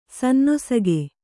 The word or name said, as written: ಸಾನುನಾಸಿಕ